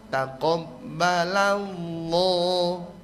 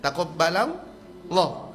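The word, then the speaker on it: takobbalallah (تقبل الله): Dan ini ada huruf qaf, jadi huruf ta tipis ta, huruf qaf tebal ke takoba. Huruf tipis lagi ba, la masih tipis, la. Nanti masuk pada Allah, alamnya ditebalkan. Jadi bacanya mestinya takobbalallah. Nah gitu, takobbalallah.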